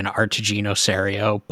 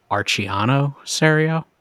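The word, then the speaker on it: Artigiano Serio: I thought I said this right by saying Artigino Serio, but he was on American Craftsman Podcast, and I think he said Archiano Serio, which sounds a lot cooler.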